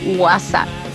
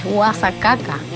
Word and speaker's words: guasacaca: Pirata, es para decirte que no es guasa, como lo estabas diciendo, es guasacaca. Guasacaca, esa es la pronunciación correcta